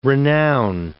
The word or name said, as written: renown